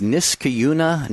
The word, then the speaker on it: Niskayuna: I'm Jerry Usher in for Cy Kellett today, and Mark Brumley will answer a question next from Chris, who is in, I believe it's Niskayuna, New York. Was I even close on that, Chris? – Yes, you pronounced it correctly.